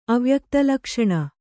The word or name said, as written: ಅವ್ಯಕ್ತಲಕ್ಷಣ